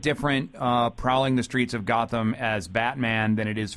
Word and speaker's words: Gotham: Yeah, now, do I have the pronunciation there right? Gotham? – Our Wisconsin accent is actually Gotham.